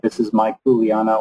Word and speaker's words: Kuleana: It's spelled K-U-L-E-A-N-A, for those who are listening. Kuleana. Kuleana.